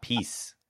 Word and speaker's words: Pease: peas like green peas, but with an E Pease. – on the end.